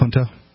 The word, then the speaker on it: Junto: Junto. – J-U-N-T-O. – Yeah. Okay. – Decades before the Southern Confederacy considered separating from the Union, New England Federalists were contemplating a secession of their own. The Essex Junto, a group of politicians, lawyers, and tradesmen that originated in Essex County, Massachusetts, was a powerful force within the Federalist Party.